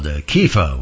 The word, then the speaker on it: Kepha: And in Hebrew, it's pronounced and spelled Kepha with a K.